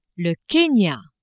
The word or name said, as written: Kenya